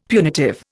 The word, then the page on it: punitive: Indian